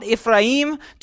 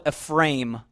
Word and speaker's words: Ephraim: I'm like, Ephraim, that's how you say it in America. And then he's like, no, it's Ephraim.